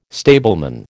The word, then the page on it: stableman: American English